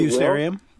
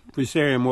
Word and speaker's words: Fusarium: And I think it's Fusarium, if I'm saying that correctly. – Fusarium? Fusarium wilt, sure.